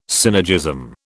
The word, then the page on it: synergism: British English